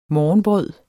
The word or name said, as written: morgenbrød